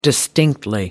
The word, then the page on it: distinctly: British English